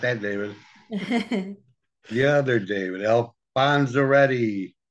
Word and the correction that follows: Alfonzetti: Alfonzetti. – Hi, David Alfonzetti. – Yeah, there's no R.